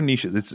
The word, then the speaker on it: niche: And is it niches or niches? It's both. – I think it's niche. Americans tend to say niche. – Yeah. – There's no T though. – Because it rhymes with rich.